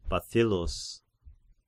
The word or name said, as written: Bathyllus